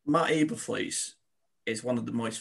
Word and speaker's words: Eberflus: Is it Matt Eberflus? – Eberflus. – Yeah.